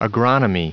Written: agronomy

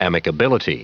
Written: amicability

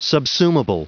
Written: subsumable

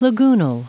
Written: lagoonal

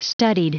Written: studied

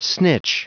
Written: snitch

